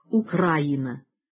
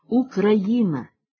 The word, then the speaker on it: Україна: Тоді як за нормами сучасної української мови в цих словах наголос повинен ставитись тільки на «ї». І єдино правильна вимова така – «Україна», «Український», «По-українськи».